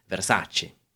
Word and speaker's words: Versace: Now, on to our final fashion house, Versace, or as it should be pronounced, Versace.